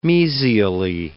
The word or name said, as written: mesially